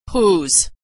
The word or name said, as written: whose